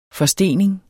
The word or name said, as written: forstening